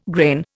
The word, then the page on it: grain: Indian